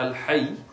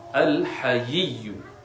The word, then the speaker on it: Al-Hayy: The first one is the name of Allah, Al-Hayy, and we need to pronounce it correctly, not Al-Hayy, Al-Hayy, with two ya's.